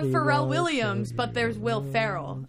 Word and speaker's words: Ferrell: I pronounce it Pharrell. – You're wrong. – Ferrell.